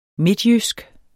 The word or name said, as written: midtjysk